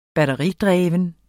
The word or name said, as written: batteridreven